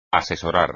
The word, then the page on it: asesorar: espagnol